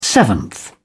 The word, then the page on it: seventh: British English